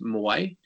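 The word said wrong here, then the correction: Moià: Did you say, or Moya? – Moya, sorry, Moya. Okay,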